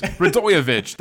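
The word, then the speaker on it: Radojević: Anyone out there knows Radojevic.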